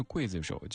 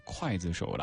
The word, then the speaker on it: 刽子手: 一首歌它红的程度可以从很多个角度得到印证，比如说这首歌它红的让您对一个字的读音有了一些习惯上的改变，明明是刽子手，结果刘天王唱了之后大家都念筷子手了。